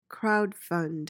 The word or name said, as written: crowdfund